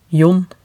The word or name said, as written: John